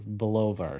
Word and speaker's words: Bulova: No, it's BULO Bulova or something like that.